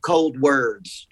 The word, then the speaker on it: ColdWards: Originally, when we started this, we were pronouncing it ColdWards, like a hospital ward, like a psychiatric ward. Uh-huh. But we, right before the release, we decided to pronounce it ColdWards, like emotion, like forwards, like towards. – Okay. – Like emotion towards the cold. So ColdWards. So it's kind of pronounced, I guess, like ColdWords.